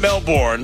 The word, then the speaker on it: Melbourne: It's not Melbourne, it's Melbourne. Or is it Melbourne? – Melbourne. Or Melbourne. Which, Melbourne. Apparently they don't pronounce the R.